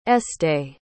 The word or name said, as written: estée